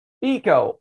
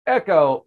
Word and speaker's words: Eco: It's not echo or shouting very loudly into a ravine, although I do practice that every day. I recommend it. It's actually pronounced eco, short for ecosystem.